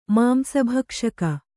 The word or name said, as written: ಮಾಂಸಭಕ್ಷಕಿ